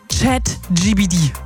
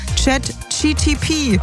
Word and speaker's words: ChatGPT: Oder wie meine Mama auch gern sagt, ChatGTP. – Oh, toll. Oh Mann, ich kenne so viele, die das so aussprechen.